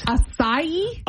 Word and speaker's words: acai: So I'm going to say acai. – What? – I know I'm messing it up. – And that is absolutely correct. – Acai? – According to this, the emphasis is on the second syllable. Acai.